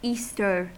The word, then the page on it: easter: American English